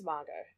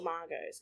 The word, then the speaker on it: Margot: But they're not real Margots. They're Margarets who call themselves Margot. – Mm-hmm. – But I'm a real Margot with the silent T.